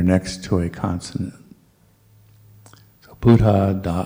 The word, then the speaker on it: Buddha: Try to pronounce both letters of a double consonant. That's also very English. In English, we don't do that, or usually don't do that. So it's Buddha, or Sabba, or Satta. So there's a little, tiny little space between the D's and the B's here and the T's.